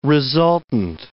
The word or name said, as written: resultant